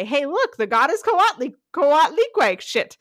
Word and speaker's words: Coatlicue: Coatlicue. Nope. Coatlicue. I have the syllable L-E-E in all caps so that I know that that's where what gets the emphasis. Coatlicue.